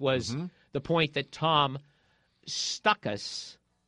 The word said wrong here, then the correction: Stukas: Mispronounced his name, and then when we went off the air, he corrected us, and it's Tom Stukas.